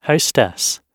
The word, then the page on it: hostess: British English